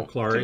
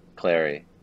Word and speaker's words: Clary: Clary. Too many people make that mistake. Clary. Clary.